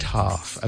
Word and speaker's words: half: The word half, okay? Because half is, of course, spelled H-A-L-F, but we don't pronounce the L.